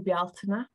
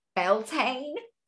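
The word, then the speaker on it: Bealtaine: Oh, see, let me just pronounce that one for you. I'd have been like Beltane. – Yeah, some people say Beltane, and they're, you know, it's okay. – Getting a language lesson as well. This is great. I love it. So pronounce that one again for me, please. – Bjaltina. – Bjaltina. Like it. – Yeah, it beautiful. Is nice, isn't it? – Yeah. Or Beltane. People do call it Beltane.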